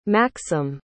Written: maksym

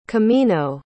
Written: camino

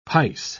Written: pice

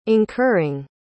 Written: incurring

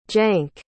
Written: jank

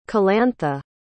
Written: calantha

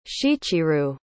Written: Shichirou

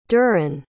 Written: durin

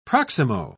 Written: proximo

